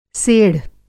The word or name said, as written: सेढ़